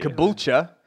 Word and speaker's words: Caboolture: Caboolture, there you Caboolture? – Go.